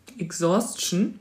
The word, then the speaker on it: Exhaustion: Exhaustion. Spricht man das so aus? – Ja. Exhaustion. – E-X-H-A-U-S-T-I-O-N. – E-X-H-A-U-S-T-I-O-N? – Exhaustion.